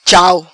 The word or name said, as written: Chau